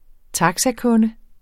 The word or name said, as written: taxakunde